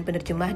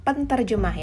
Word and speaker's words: penerjemah: Eh, sebelum saya lupa, pengucapan dan ejaan yang benar adalah penerjemah, bukan penterjemah ya. Karena huruf T akan lesap setelah bertemu dengan awalan P, setelah diawali eh, diawali dengan awalan P dan berubah menjadi N.